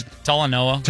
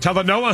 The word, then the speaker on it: Talanoa: Talanoa. – Talanoa Hufanga.